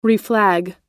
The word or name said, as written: reflag